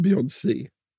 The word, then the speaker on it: Beyoncé: Jag säger Beyoncé. Ja, det första. Beyoncé. – Ja, ja, det är bra. Ja, det är rätt.